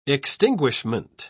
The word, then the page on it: extinguishment: British English